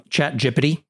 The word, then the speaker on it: ChatGPT: Yeah, on some level, I can't shake the feeling that it's still very early days there for ChatGPT. Yes, that's how I pronounce it.